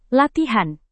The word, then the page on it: latihan: indonesian